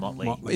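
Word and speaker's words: Mötley: Yeah, but it's actually pronounced Motley.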